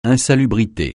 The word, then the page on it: insalubrité: francais